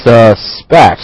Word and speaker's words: suspect: I suspect. – Suspect. – Suspect. – Right. 所以大家呢经过一定的练习把这个词的它的名词和动词的意思呢弄清楚同时它的发音呢能够有效的区分出来。 – Yeah. So just remember the verb is a little bit slower. You draw out the sound.